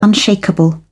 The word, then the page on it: unshakeable: British English